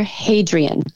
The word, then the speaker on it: Hadrian: What's his name? Adrian? I don't think I'm saying that right. – Yeah, it starts with an H. It was... – Emperor Hadrian. Hadrian or Hadrian? – Yeah, I think you got it.